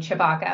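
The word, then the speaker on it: Tshibaka: And her last name is pronounced, you told me to pronounce it Chewbacca, but it's actually T-S-H-I-B-A-K-A. I am saying it right, right? – You are. The force is with us, and my husband is kind of an African immigrant, so it's an African name, Chewbacca.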